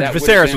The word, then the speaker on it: Viserys: Viserys, or Viserys was with, his wife.